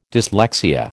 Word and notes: dyslexia: American English

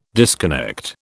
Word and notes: disconnect: British English

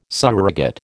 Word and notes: surrogate: American English

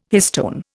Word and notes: histone: Indian

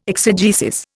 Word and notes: exegesis: Indian

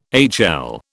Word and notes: HL: British English

